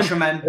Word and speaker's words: Osimhen: I like Victor, I can never pronounce his name, Osimhen. – Osimhen, yeah.